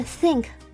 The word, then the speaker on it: think: 接下来是 think think，这个字的 th 呢，我们的舌尖要放在上下排牙齿的中间，发出一个 s 这样子的声音。